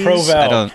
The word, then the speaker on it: Provel: Provel.